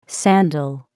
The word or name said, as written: sandal